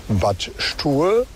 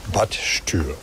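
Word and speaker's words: Bad Stuer: Und etwas weiter südlich gibt es den Ort Bad Stuhl. Der wird mit UE geschrieben und deshalb sagen die Leute häufiger Bad Stuhl.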